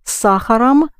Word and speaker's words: сахаром: So you have с молоком, where ом is clearly heard, it's accented, but the same ом с сахаром, but it's not accented.